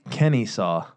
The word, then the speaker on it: Kennesaw: Kennesaw.